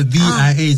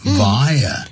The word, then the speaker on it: via: 我要刚才讲说透过百度，你刚才 VIA 怎么念的呢？ – Via、但是呢它有两个念法、 Via 也可以念，或者 Via 也可以念、就是 by way of by, by, 再加个 way, way, by way of 的意思、借由这样子。